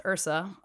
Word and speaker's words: IHRSA: Um, it's it's pronounced ursa but it's i-h-r-s-a okay.